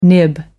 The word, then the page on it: nib: American English